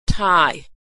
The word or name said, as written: Thai